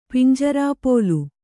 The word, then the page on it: ಪಿಂಜರಾಪೋಲು: pinjarāpōlu